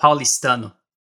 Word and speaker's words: Paulistano: And have I pronounced that right, or have I mangled it? I bet you I mangled it. – You were very close to it. It's called Paulistano.